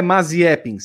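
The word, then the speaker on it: Mazepin: Ah, é Maziepin, sim, essa é a pronúncia do é glorioso.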